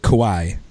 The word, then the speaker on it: Kauai: Kauai. – Kauai, like Kauai Leonard for the San Antonio Spurs.